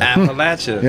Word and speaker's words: Appalachia: But she's the one that coined, I'll throw an apple at you. And that's how you say it. Apple at you. – Appalachia.